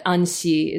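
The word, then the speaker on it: Anthy: You can Romanized Anthe is fine, but Antsi is how it's pronounced in Japanese, but Romanized, yeah.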